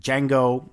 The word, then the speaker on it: Django: Django, the D is silent, bij een kampvuur zitten.